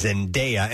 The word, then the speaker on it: Zendaya: Until she officially came said, you know, somebody asked her, how do you say it? And it's Zendaya, and it's spelled that way, D-A-Y-A, or the last... the last part of her name, so Zendaya, and she's great.